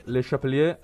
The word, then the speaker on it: Le Chapelier: 1791 la legge Le Chapelier, questa era bella, mi è venuta bene la pronuncia, dai. – Le Chapelier, sì, è famosa.